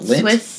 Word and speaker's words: Lindt: Is that how you spell it? Lint? Or say it? – Yes. L-I-N-D-T. – You just call it Lind? – The Swiss company, Lind.